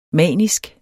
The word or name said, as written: manisk